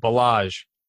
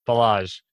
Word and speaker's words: Ballage: So, okay, gonna help you out a little bit, Ballage. Ballage.